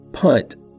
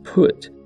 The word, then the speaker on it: Punt: Punt, sometimes pronounced Put, is thought to have been the same as the modern-day country of Somalia, in a region known as the Horn of Africa.